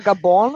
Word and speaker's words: Gabon: Da kommt dann immer Gabon raus. Ja, weil es wird so ausgesprochen in der Landessprache.